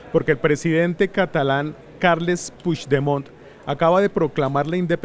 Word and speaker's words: Puigdemont: ¿Lo dije bien? Puigdemont. Sí, tal cual.